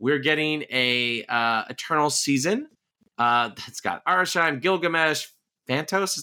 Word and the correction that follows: Phastos: is that how you say it? – Phastos. – Phastos, Circe, Athena.